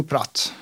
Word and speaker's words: Pratt: Alltså, vi säger Hugo Pratt.